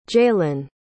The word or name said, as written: jaylan